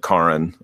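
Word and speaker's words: Karyn: And so, Destroyer, the new film by Karen, I've heard it pronounced. – Yeah, me too. – William Friedkin actually said Karen. So Karen Kusama.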